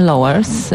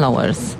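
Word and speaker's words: Lauwers: Ja wiem tutaj ze źródeł bardzo sprawdzonych, bo od ambasady, że mówi się Lowers, Jan Lowers.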